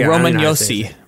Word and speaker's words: Roman Josi: Is Roman Josi? – Roman Josi.